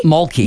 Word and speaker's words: Mulkey: Mulkey.